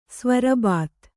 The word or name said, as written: ಸ್ವರಬತ್ತು